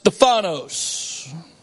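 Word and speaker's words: Stephanos (στέφανος): The Greek word there's pronounced Stephanos. It was not a diademia, a crown of royalty, but it was a victor's crown.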